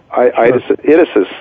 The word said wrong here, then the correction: Idesis: Am I pronouncing Idesis, it rhymes with thesis. – Idesis rhymes with thesis.